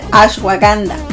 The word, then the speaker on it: ashwagandha: Siempre lo digo mal, pero es el ashwagandha.